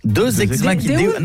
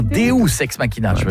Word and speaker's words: Deus ex machina: Qu'est-ce qu'un deus ex machina ? – Quoi ? – Deux ex machina. Non, Deus ex machina.